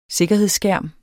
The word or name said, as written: sikkerhedsskærm